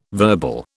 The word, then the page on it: verbal: British English